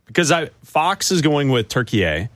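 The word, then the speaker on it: Türkiye: They pronounce it turkey A. – Turkey A. – It's pronounced turkey A.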